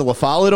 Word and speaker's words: La Follette: Well, I mean, my second favorite is Robert Lafayette. – Yeah. They usually pronounce it La Follette over there. I don't know if it's like a weird Buffalo pronunciation.